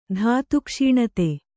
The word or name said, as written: ಧಾತುಕ್ಷೀಣತೆ